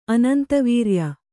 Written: ಅನಂತವೀರ್ಯ

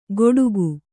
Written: ಗೊಡುಗು

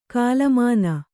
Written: ಕೋಲುಮೀನು

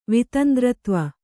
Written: ವಿತಂದ್ರತ್ವ

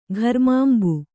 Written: ಘರ್ಮಾಂಬು

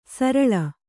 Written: ಸರಳೆ

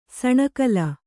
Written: ಸಾಣೆಕಲ್ಲು

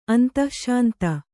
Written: ಅಂತಶ್ಶಾಂತ

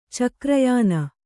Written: ಚಕ್ರಯಾನ

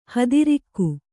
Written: ಹಾದರಿಗ

